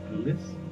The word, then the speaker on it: Clueless: de esta película de Idea o Clueless. Ahora, ahora sí lo dije bien, Clueless, Idea. es correcto. Exacto.